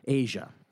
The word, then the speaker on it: Aja: So he appears on the Royal Scam in 1976, and then does Aja on 77. – Yep. – Right? – Yep. – Am I saying it right? Aja. – Asia. – Is it Asia? – It is Asia. It looks like Aja.